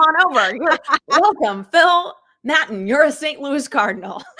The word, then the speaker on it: Maton: Is that how you say it? Maton? – Maton.